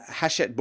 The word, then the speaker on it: Hachette: This is from, Gigamic Games, I'm not sure how you pronounce it, but then Hellshed Games have brought this over. Actually, let me see if I can pronounce that properly, because that was not good. Gigamic Games, G-I-G-A-M-I-C, how else would you pronounce it?